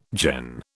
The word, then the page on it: gen: British English